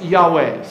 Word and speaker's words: Yahweh: Uh, the name of God would be pronounced Yahweh.